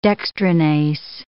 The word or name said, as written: dextranase